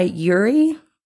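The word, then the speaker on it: yūrei: In Japanese folklore, the Aokigahara Forest is said to be haunted by Yuri. I don't know if I'm saying that right. It's Y-U-R-E-I,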